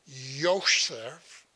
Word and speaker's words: Yosef: Okay, so Miriam and Yosef, you pronounce the J with a Y sound, sort of a German type thing, and these two were people of very strong faith, I believe.